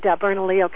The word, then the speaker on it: Bernalillo: In many precincts all around the state, of course centering on Albuquerque, which is Bernalillo County, I've learned how to pronounce the names of the counties in New Mexico. – That's good. That's good. That double L will get you every time.